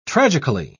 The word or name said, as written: tragically